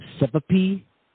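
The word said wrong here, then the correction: ćevapi: It starts with the C. If so, can you explain to the listeners the dish is all about? – It's Cevapi. So it's like, yeah, so it's Cevapi, I think.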